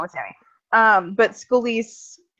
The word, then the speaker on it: Scalise: Is it Scalise or Scalise? – Scalise.